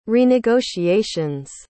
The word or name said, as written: renegotiations